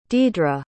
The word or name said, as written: deidra